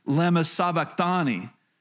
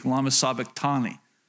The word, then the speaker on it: lema sabachthani: Matthew 27 says this, about 3 o'clock in the afternoon, Jesus cried out in a loud voice, "Eli, Eli, lema sabachthani," is how you say that. If you didn't know, I just trust me, I did the research this week, lema sabachthani, which means, my God, why have you forsaken me?